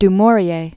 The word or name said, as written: du Maurier, Daphne